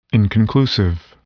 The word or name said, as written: inconclusive